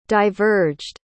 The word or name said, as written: diverged